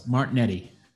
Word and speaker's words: Martignetti: Martignetti.